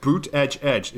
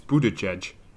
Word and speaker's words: Buttigieg: Um, it's uh, Boot Edge Edge. It's Buttigieg.